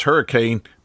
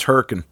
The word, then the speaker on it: Hurricane: It might look like, if you saw it on a map, that it's Hurricane, but it's not. It's Hurricane.